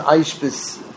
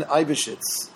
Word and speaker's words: Eybeschutz: Rav Yonasan Aishbis, big rabbi. And, anyway, this is his idea, that we're basically mimicking God. We're mimicking God. E-Y-B-E-S-C-H-U-T-Z. That's why it's hard to pronounce. Rabbi Jonathan Aibishitz from the 18th century.